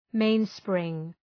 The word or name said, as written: mainspring